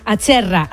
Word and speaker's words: Acerra: A ver si lo digo bien, ¿es Acerra o Acerra? – Acerra.